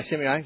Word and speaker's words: Shimei: Am I saying that right? – Yeah, Shimei.